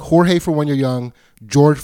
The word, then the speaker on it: Jorge: It's spelled J-O-R-G-E, and you never know if that name is pronounced Jorge or George.